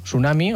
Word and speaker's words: tsunami: En tsunami tenim dues opcions. Podem fer tsunami o tsunami. Jo crec que és més còmode sense fer la T inicial.